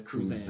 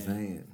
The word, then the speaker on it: Cruzan: Like, what you got to say, young Cruzan? We've called him like five different ways. – It's Cruzan. Cruzan.